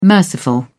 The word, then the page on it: merciful: British English